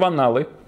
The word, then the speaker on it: анналы: Эта история войдет в анналы. Слово опасное, но я произношу его тщательно, с двумя буквами «Н».